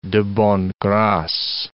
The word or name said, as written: de bonne grace